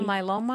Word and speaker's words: myeloma: Multiple myeloma, I think I'm saying that right. – Yep. Okay, mm-hmm. Yeah, that's exactly right.